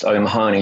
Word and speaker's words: O'Mahony: J-O-M. – J-O-M, correct. My surname, which you just pronounced O'Mahony, is often butchered in the United States. So I said it's really much easier to go by J-O-M. Incidentally, I learned how to pronounce my surname in this country when I watched Police Academy years ago.